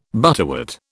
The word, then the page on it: butterwort: British English